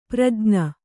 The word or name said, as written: ಪ್ರಜ್ಞ